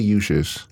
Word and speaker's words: Uchis: Oh, how do you pronounce her last name? – Kali Uchis. – Uchis. – Yeah.